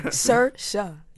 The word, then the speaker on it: Saoirse: Saoirse Ronan. – We're just beginning of Cersei. – Saoirse. – Yeah, there we go.